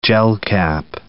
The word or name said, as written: gelcap